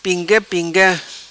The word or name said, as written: Pinga Pinga